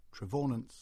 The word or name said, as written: Trevaunance